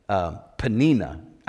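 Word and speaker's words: Peninnah: There are different things that we've preached about Hannah and about I always say Penaniah, but her name actually is pronounced, I think, Penina, actually, right? Like Panini. I think it's actually pronounced Penina.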